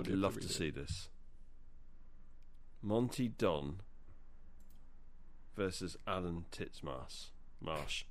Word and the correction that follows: Titchmarsh: I can't even pronounce his name. Titchmarsh.